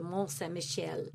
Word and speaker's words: Mont-Saint-Michel: It is the Marathon de la Baie de Mont-Saint-Michel, and it is in Normandy in France.